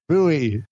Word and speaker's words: buoy: But yeah, that's where you get out the Google and you type in pronounce boy. Now I can't even say it. – Buoy. – Pronounce buoy. You got to just power through it.